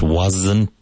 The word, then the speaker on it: wasn't: It wasn't my fault. It wasn't my fault. Make sure you pronounce the T at the end of wasn't. So the S in was sounds like a Z or a Z.